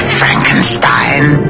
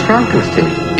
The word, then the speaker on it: Frankenstein: Dr. Frankenstein. – It's pronounced Frankenstein.